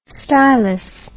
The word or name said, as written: stylous